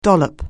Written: dollop

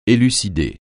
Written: élucider

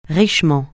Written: richement

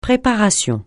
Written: préparation

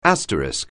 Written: asterisk

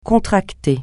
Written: contracter

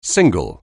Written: single

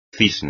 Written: cisne

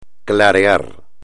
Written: clarear

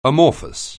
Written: amorphous

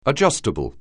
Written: adjustable

